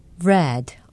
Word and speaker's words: read: Read, R-E-A-D, read, 읽었다라는 뜻인데요. 읽다라는 표현을 할 때도 철자는 같습니다. R-E-A-D, 그러나 발음은 read라고 합니다. 과거와 과거 분사는 read, 읽다, 독서하다는 read입니다.